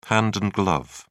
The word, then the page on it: hand and glove: British English